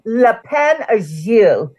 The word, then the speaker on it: Lapin Agile: Picasso at the Le Pont Argile. Is that what? How's that? – That was very good and very close. Le Pont Argile, which is the name of a bar in Paris.